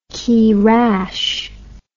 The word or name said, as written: keerash